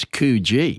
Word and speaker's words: Coogee: It's Coogee. – Yeah, which is the right way to pronounce it. – Okay. – Yeah, you got it wrong. So I must let all those people in Sydney know. – Yeah, let them know. It's Coogee.